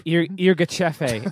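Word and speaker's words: Yirgacheffe: Jürgerschefe, as I've heard it pronounced.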